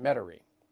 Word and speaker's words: Metairie: I think that's how you say it, Metairie.